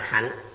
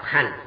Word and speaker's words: hạnh: Chữ hành này thời xưa đọc là hạnh, đọc âm phá, dùng như động từ. Người thời nay không mấy chú trọng những thứ này, đọc hành cũng được, chúng ta tùy tục, chỉ cần mọi người hiểu nghĩa này là được rồi.